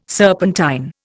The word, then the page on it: serpentine: Indian